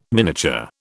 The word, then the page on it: miniature: British English